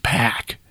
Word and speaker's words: Pak: The artwork entitled Merge by the artist called Pack, that's spelled P-A-K, was sold for $91.8 million.